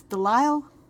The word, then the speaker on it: DeLisle: Emmett says it's DeLisle. I want to pronounce that S. It's just there in the middle of the word. Makes me want to say it.